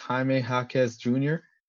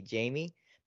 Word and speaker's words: Jaime Jaquez: So if you pronounce it in English, it'd be Jamie. But because he's of Latino descent, it is Jaime Jaquez Jr. Jaime Jaquez Jr. But something about this Heat team.